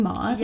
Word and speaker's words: Maud: Is that how you say her name, Maud? – Yes, yeah, Judd's kid.